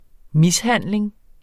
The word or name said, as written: mishandling